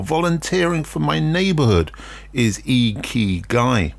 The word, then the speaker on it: Ikigai: Ikigai, I-K-I-G-A-I, as I said, pronounced Ikigai, is a word you hear a lot in Japan.